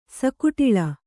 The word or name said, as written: ಸಕುಟಿಲ